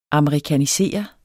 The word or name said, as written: amerikanisere